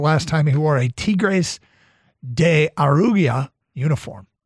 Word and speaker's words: Tigres de Aragua: Tigres de Aragua.